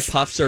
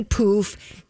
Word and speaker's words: pouf: Because you say puff, I say poof. Somebody emailed me and said it's spelled P-O-U-F and it's pronounced poof.